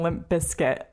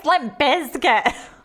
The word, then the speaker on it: Limp Bizkit: Because people were saying Limp Bizkit, and I remember that really annoying me. – You gotta pronounce the Z, right? – Yeah, you have to pronounce it. So I'm like toddling around backstage being like, it's Limp Bizkit.